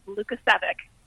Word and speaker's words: Lukacevic: That's closer to the European version, but the American version is Lukacevic.